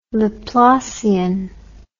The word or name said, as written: laplacian